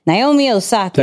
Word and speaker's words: Naomi Osaka: Naomi Osaka.